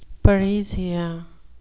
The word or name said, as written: parrhesia